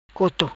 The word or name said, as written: Coto